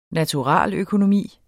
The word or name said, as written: naturaløkonomi